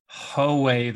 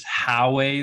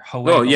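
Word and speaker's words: Howay: If it's Ho-way the lads versus How-way the lads. – Yeah. – You've got it right there. Ho-way lads is the right way to say it.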